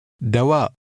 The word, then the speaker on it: دواء: Remember to pronounce the glottal stop at the end of the word. – دواء.